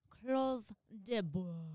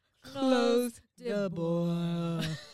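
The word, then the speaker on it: Clos du Bois: So it's close de bois. – That's how you pronounce it? – It's like you're yawning. – Close the boy. – You gotta open the mouth.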